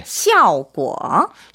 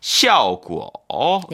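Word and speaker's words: 샤오구어 (效果): 샤오구어. – 샤오구어.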